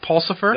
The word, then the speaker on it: Pulsipher: Uh, you you also worked with uh, Richard Bushman, and then there's is it, am I going to say this right? David Pulsipher. That's right.